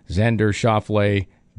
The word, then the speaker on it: Xander Schauffele: Then the players at three under, Patrick Reed, Paul Casey, Patrick Cantlay, Ches Reavy, Xander Schauffele, Daniel Berger, Justin Thomas, and Camilo Vigegas.